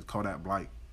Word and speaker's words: Kodak Black: And that's how them people in Florida say his name, Kodak Blight.